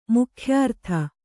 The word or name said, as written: ಮುಖ್ಯಾರ್ಥ